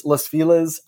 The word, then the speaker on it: Los Feliz: Los Feliz. I'm just going to say that because that's what people have said to me when I've said it other ways.